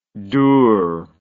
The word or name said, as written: dourness